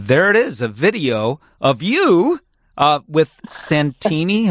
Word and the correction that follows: Santino: Have I got the name right? – Santino. – Yes, sorry, yes, Santino.